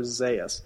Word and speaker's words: Zaius: And then you have Dr. Zaius. Excuse me, I've been saying it wrong all this time. Dr. Zaius.